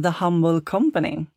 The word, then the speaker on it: The Humble Company: Från The Humble Company.